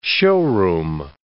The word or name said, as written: showroom